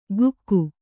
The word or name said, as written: ಗುಕ್ಕು